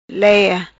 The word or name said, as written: Leia